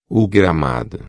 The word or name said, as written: gramado